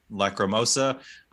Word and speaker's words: Lacrimosa: Lacrimosa of Dana.